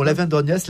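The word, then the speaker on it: Aven d'Orgnac: On continue nos pérégrinations souterraines à Lavandorgnac, c'est comme ça qu'on prononce ? – Absolument, Lavandorgnac,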